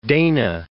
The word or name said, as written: Dana